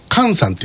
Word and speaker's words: 和: で、実はねこれあのかんさんって木村かんさんって言うんですよ。本名があの昭和の和って書いてかんって読むんですね。